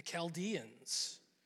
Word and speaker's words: Chaldeans: And it was ruled by a people called the Chaldeans. It rhymes with Schmaldeans.